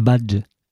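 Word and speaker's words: Batlle: En 1904, José Battle y Ordonez, tu peux le prononcer correctement? – Badge. – À Badge. Y Ordonez.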